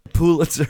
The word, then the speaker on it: Pulitzer: Pulitzer.